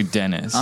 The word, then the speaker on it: Denis: Because it's spelled D-E-N-I with a little accent mark over it and when one S. So it's actually Dennis.